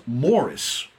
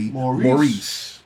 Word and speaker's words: Maurice: Forster, and the novel was Morris, or as we Americans say, Maurice.